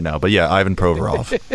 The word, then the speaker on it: Provorov: Provorov? – Yes.